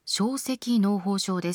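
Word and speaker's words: 掌蹠膿疱症: 今回のテーマは小赤嚢胞症です。手のひらという漢字に足辺に庶民の書と書いて赤と読む小赤嚢胞症、これ初めて聞いたんですけれども、どんな病気なんでしょうか。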